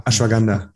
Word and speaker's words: Ashwagandha: Äh, Ashwanda, richtig ausgesprochen? – Ashwagandha. – Ashwagandha. Ja.